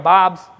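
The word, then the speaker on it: Báb: And this is the year of Bob's, that's the correct pronunciation, manifestation according to the actual text of the book of Daniel.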